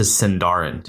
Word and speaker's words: Sindarin: Also, why did the elves feel the need to change their language from Quenya to Sindarin?